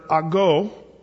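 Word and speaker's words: ago (ἄγω): The word there in the Greek is the word ago, A-G-O, is the way it's actually phonetically written, ago.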